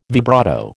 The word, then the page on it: vibrato: American English